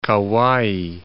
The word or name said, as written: Kauai